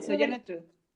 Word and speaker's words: Sojourner Truth: Está bien, es un nombre complicado, Sojourner Truth.